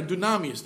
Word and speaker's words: dunamis: You know, it comes from the word dynamos. I'm sorry, dunamis. This is the way you pronounce it.